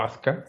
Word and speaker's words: Asuka: pero eso en japonés sería Azúcar o Aska. – Asuka, los japoneses no pronuncian la U. No es porque los gringos no, es porque los japoneses no la pronuncian.